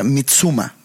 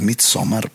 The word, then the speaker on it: Midsommar: Y ahora que fuimos a Suecia, como que aprendí bien leve cómo se las pronunciaciones de vocales y así. Y, y ahí pensé, estando en Suecia, fue que pues es Midsommar. ¿Por qué el vato me trataba de impresionar diciendo Mitsuma?